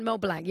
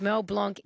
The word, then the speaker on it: Mel Blanc: Mel Blanc.